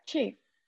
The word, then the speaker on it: Qi: Yeah, that's how you pronounce it. Yes, you're correct. Qi.